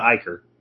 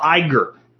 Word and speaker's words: Iger: And when this all happened, no, it's not Iker. It's Bob. No. I got to look it up now because you guys are all wrong. CEO of Disney. I always get his name wrong. Bob Iger. Iger. I-G-E-R. Bob Iger. So somebody had it close. Iker.